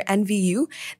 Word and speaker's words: Enviu: Started in 2021, Tupperware is one of the ventures parked under NVU, that's spelled as ENVIU, which is a Dutch social venture building company.